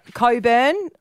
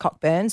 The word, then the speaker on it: Cockburn: Coburn, a lot of eastern states people think it's pronounced Cockburn, so we could just change it to Richard.